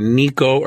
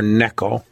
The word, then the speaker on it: Necho: But one guy to remember is spelled Necho, N-E-C-H-O, but it's pronounced either Nico or Necho. I'll probably use Necho.